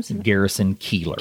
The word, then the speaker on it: Garrison Keillor: Garrison Keillor.